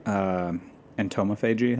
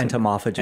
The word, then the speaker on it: entomophagy: I mean, so you know, a lot of the information that's out there about entomophagy, how do you pronounce entomophagy. – It? – Entomophagy. – Entomophagy. Got it.